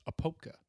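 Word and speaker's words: Apopka: So yeah, actually in Opopka. – I believe the proper pronunciation is Opopka.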